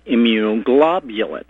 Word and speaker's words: immunoglobulin: They can give me an infusion of immunoglobulin.